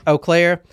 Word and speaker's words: Eau Claire: Eau Claire.